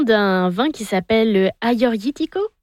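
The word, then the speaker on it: Agiorgitiko: Vous êtes très bonne, parce que pour ceux qui nous écoutent, le Aioritiko, en fait, c'est deux G, mais les G, on les prononce comme les Y.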